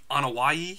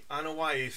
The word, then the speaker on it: Anoa'i: The Anoa'i? – The Anoa'i family.